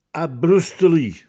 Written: Abbrustelì

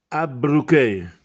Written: Abbruchè

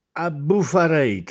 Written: Abbufarète

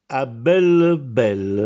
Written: A bell bell

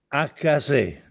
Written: Accasè

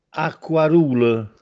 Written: Acquarùle

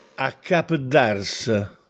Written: Accapeddàrse

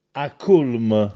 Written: Accùlme

